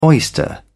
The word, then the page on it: oyster: British English